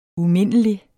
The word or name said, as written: umindelig